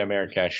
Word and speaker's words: Marrakech: 6-3 to win the Grand Prix to Hassan in Marrakech, I believe is how you pronounce that. – Yeah, Marrakech.